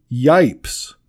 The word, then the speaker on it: Yipes: His name is Yipes. – Of course. – Rhymes with stripes, right?